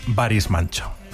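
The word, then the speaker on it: Barış Manço: es Baris Mancho.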